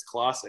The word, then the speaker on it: Clase: That's just so did you not know it was Clase? – No, I don't watch the Indians. I have him on my fantasy team. – Dodgers player. – People pronounce his name all the time, and it's Clase. It's never been.